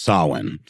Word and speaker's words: Samhain: And if you want to sound smart in front of your friends, or at the very least not sound dumb, it's not pronounced Samhain. It's pronounced Samhain. – Samhain, that's correct. When you see it printed, though, it looks like Samhain.